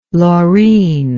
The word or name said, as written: Laureen